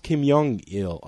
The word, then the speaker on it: Kim Jong-il: It's pronounced Kim Jong-il, I guess.